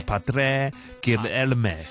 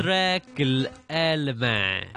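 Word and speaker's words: Padre Guilherme: 咁呢位朋友咧就係、是叫帕，即係因為佢係一個葡萄牙嘅人啦，所以佢嗰個發音咧係應該係西班牙文嚟嘅，但係就係 Padre Guillerme，即係類似係咁啦。 – Padre Guillerme 係啦，咁 Padre 即係 father 咁解啦，西班牙文。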